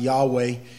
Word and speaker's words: Yahweh: The word Lord, as it appears in Scripture there to you, capital L-O-R-D, is the name of God, his proper name, Jehovah, or more appropriately pronounced Yahweh. And it, whenever you see capital L-O-R-D in your Old Testament, that's the name, the covenant name of God to his people.